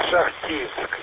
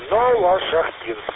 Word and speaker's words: Новошахтинск: А у вас Новошахтинск. Это 100 километров от Ростова-на-Дону. Новошахтинск. Новошахтинск. Вернее, так, ударение сделать так будет вернее.